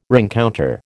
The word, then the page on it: rencounter: American English